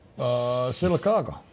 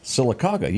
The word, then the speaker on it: Sylacauga: Sylacauga. – Sylacauga.